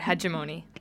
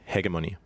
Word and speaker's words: hegemony: So the word of the day today is, it's actually pronounced two different ways. I'm from Canada, so over there we call it hegemony. – Yeah, I'm from Europe, and here we call it hegemony. – But we debated about this for a while, and we realized that it actually is pronounced both ways. It just depends where you're coming from.